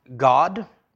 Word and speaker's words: Gad: Gad is pronounced God.